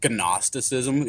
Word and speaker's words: Gnosticism: esoteric Christianity is often referred to as Gnosticism, like spelled with a G, like Gnosticism.